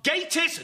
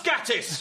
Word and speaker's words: Gatiss: So, please welcome Mark Gatiss, not Gatiss.